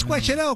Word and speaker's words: Coachella: ¿Es Coachella o Coachella? – Sí, Coachella, lo dije bien.